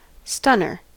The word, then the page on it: stunner: American English